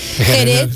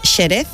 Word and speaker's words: Jerez: Jerez, en principio era con una X, pero ahora se escribe con J, y se pronuncia con J. Nadie dice Xerez.